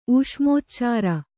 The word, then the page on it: ಊಷ್ಮೋಚ್ಚಾರ: ūṣmōcchāra